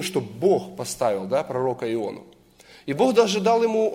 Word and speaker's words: Иону: Я специально сделал ударение, пророка Иону, проповедника.